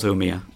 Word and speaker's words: Umeå: I'm losing confidence about pronunciation now. Umeå. – It definitely was Umeå.